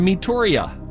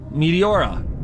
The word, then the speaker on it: Meteora: Zero, spelled with an X, like X-E-R-O. X-E-R-O? Reanimators or Meteoria? Meteoria. Meteora. Meteora, that's it.